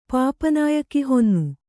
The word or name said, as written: ಪಾಪನಾಯಕಿಹೊನ್ನು